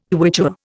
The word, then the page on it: Quichua: Indian